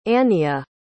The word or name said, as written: Aniyah